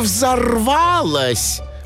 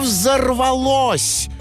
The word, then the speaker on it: взорвалось: И поэтому оно так взорвалось? – Не взорвалось, а взорвалось. Ударение надо правильно ставить.